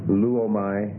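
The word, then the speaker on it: luomai (λύωμαι): Number eight, first person singular present middle and passive subjunctive, luomai. That's lambda, upsilon, omega, mu, alpha, iota. Lambda, upsilon, omega, mu, alpha, iota. And the stress on the first syllable.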